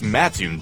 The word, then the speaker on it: Mattoon: Is it Mattoon or Mattoon? – It's Mattoon, dummy.